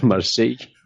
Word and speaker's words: Marseille: bueno, volviendo al programa, al 2x33, eh, yo tengo, la serie Marsella o Marsellé, no sé cómo se pronuncia Marseille, en francés. – Marseille.